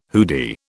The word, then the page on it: hoodie: British English